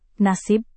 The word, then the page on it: nasib: Indonesian